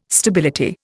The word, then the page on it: stability: Indian